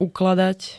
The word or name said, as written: ukladať